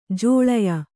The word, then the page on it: ಜೋಳಯ: jōḷaya